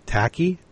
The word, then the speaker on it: Tachai: Thailand has closed how do you pronounce this? Tashi? – Yeah, T-A-C-H-A-I.